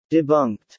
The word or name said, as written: Debunked